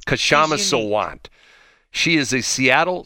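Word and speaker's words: Kshama: Her name is Kashama. I guess I pronounce it correctly. It's K-S-H-A-M-A. Would you say Kashama? – Yeah, I would. – Her last name is Sawant, S-A-W-A-N-T.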